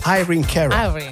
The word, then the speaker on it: Irene Cara: Però la pronuncia corretta è Irina. – No, Irene Cara.